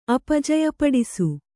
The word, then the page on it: ಅಪಜಯಪಡಿಸು: kannada